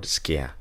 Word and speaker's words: skia: It says, For the law, having a shadow, the word shadow there is the Greek word skia. It's spelled S-K-I-A.